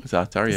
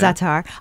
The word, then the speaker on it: za'atar: Za'atar, yeah. – Za'atar.